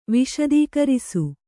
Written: ವಿಶದೀಕರಿಸು

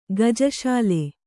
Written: ಗಜಶಾಲೆ

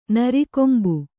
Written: ನರಿಕೊಂಬು

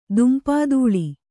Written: ದುಂಪಾದೂಳಿ

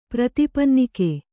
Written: ಪ್ರತಿಪನ್ನಿಕೆ

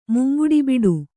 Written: ಮುಂಗುಡಿಬಿಡು

